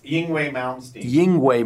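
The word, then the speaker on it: Yngwie: He finds a guitar and smiles, loads Yangwei, I'm sorry, how do you say it? Sorry? – Yingwei Malmsteen.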